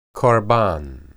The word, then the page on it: qorban: hebrew